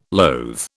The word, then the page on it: loathe: British English